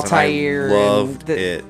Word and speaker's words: Altair: It should be, but the correct pronunciation is Altair, but...